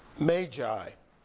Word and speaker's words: Magi: Now, after Jesus was born in Bethlehem of Judea in the days of Herod the king, behold, Magi is the correct pronunciation. It's always hard to say that, isn't it, after all these years of Magi, but Magi came from the east, arriving in Jerusalem, saying, "Where is he who has been born king of the Jews?